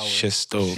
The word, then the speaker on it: Gestow: Gestow.